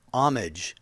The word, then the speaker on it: homage: but the first one they say is how it's pronounced is homage. – Homage. – Without the H, but the short O.